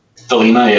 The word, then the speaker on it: Thelema: Now, I'm not sure how to pronounce this. Is it the Thelema? – Thelema, yeah.